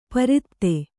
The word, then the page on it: ಪ್ರತಿಪತ್ತು: kannada